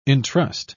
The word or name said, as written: entrust